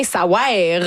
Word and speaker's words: Ça ouaire: Ça veut rien, ça ouaire. Vraiment, ça s'écrit de même, il faut le prononcer comme ça.